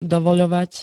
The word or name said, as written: dovoľovať